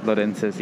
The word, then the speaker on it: Lorenzis: Is that how you pronounce your last name? – Lorenzis. Yeah, it's actually... De Longense, yeah, but like, it's too, you know, like, I don't... – It's too formal? – I just don't tell people to say it like that, you know? – I feel that.